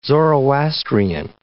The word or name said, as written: Zoroastrian